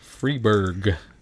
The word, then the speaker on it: Freberg: Yeah, so there you go, little Stan Freberg. I have to say it like that because we were just talking about Skynyrd, so Freebird. Freebird. – Freberg.